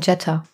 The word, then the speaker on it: Jetta: Du hast auch ein Bild von dem Auto, dem Jetta, habe ich es richtig ausgesprochen? – Ja.